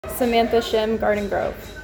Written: Samantha Shim

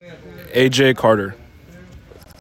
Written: AJ Carter

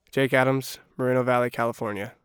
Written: Jake Adams

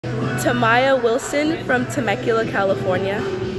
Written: Tamiya Wilson

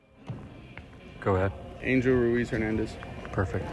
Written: Angel Ruiz-Hernandez

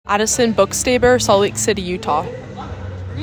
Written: Addison Bookstaber

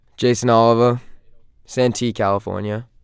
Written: Jayson Oliver

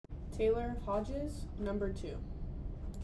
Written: Taylor Hodges

